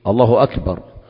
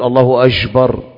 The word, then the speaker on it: الله أكبر: طبعا يعلم الناس النطق الصحيح في الله أكبر، فبعضهم يقول الله أجبر بجيم أو أكبر، الله أكبر، الله وأكبر، الله أكبر، الله أكبر، كل هذا مخالف.